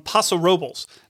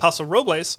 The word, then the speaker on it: Paso Robles: What I have picked is the Josh Reserve Cabernet Sauvignon from Paso Robles. Now, I know you think I should say that Paso Robles, but it's actually truly pronounced Paso Robles.